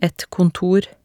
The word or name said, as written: et kontor